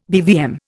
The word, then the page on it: BVM: Indian